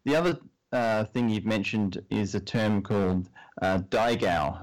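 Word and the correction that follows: daigou: So Dai Go is actually a term from Mandarin.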